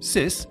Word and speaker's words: Scis: On his latest album, Sis, spelled S-C-I-S but pronounced Sis, Markus Popp sounds unusually playful and cheerful, 25 years after his genre-defining debut, and even uses his sampled versions of analog instruments.